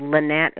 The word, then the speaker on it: Lenette: And my name is spelled Lynette, is L-E-N-E-T-T-E, spelled exactly the same.